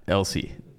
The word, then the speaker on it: Elsie: I thought it was a silent S. – It's not. Elsie.